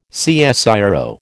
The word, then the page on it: CSIRO: American English